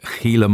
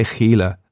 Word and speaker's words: Gila: Now, I say Gila, That's Spanish, with the ch. Now, I know in American English it's pronounced with a h, Gila Monster, but I can't help but do it Spanish, see?